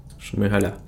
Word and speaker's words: Шмигаля: як правильно робити наголос ти типу, писав? Шмигаля, так? Шмигаля.